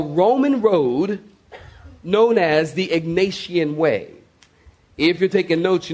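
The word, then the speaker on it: Egnatian: Paul and Silas are traveling on a Roman road known as the Ignatian Way. Let me spell it. E-G-N-A-T-I-A-N. E-G-N-A-T-I-A-N. The Ignatian Way.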